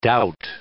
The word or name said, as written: dought